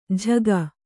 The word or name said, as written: ಝಗ